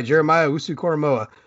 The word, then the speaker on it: Jeremiah Owusu-Koramoah: I suppose at this point, Jeremiah Owusu-Koramoah, I think is how you pronounce the name. Did I get that anywhere close to right? – Yep, you got it absolutely right. Jeremiah Owusu-Koramoah.